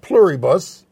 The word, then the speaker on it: Pluribus: Christian Cooper, has our movie reviewer and sometime co-host, has seen Pluribus.